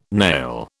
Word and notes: nail: British English

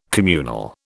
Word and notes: communal: British English

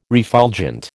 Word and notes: refulgent: American English

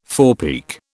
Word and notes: forepeak: British English

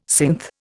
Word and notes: synth: Indian

